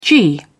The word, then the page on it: g.man: British English